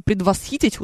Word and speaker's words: предвосхитить: Может быть, вы, наконец, выяснили, что в слове «предвосхитить» ударение ставится именно так.